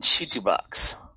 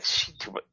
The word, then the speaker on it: Chitubox: You use one called Chitubox. – Chitubox. – It sounds like that, right? Almost spelled like it too. Chitubox. It's, I think you say Chitubox, I think, because it's from Jida. So, but that's how it will do, the slicing for you of your model.